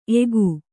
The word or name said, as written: ಎಕ್ಕು